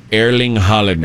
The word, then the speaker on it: Haaland: But I was just saying Hovland. – Oh, well, you just made up a different word that's different. – That's how you say it, right? – Erling Haaland. – Erling Haaland. Erling Braut. His middle name is Braut. – Braut. – B-R-A-U-T.